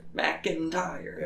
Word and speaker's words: McIntyre: You got to emphasize each syllable. – McIntyre.